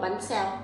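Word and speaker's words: Bánh xèo: Bán xèo. – Bán xèo.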